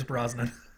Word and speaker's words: Brosnan: It just explains too much because, I can kind of see where Dr. Trace's role was meant for Pierce Bronson. Am I mispronouncing his name again? – Yes, it's Pierce Brosnan.